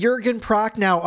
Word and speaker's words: Jürgen Prochnow: Yes, Jürgen Prochnow of Das Boot.